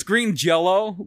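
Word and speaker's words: Green Jellÿ: So it's spelled jelly, but they always said, yeah, J-E-L-L-Y, it's pronounced Jell-O.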